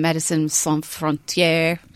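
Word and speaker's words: Médecins Sans Frontières: He's a he goes to he's a humanitarian, so he flies over with the likes of um, Medicine Sans Frontières.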